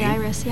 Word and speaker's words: Jairus: Jairus, yeah.